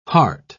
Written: heart

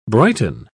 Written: brighten

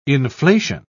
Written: inflation

